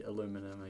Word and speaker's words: aluminum: Aluminum and some people say aluminium.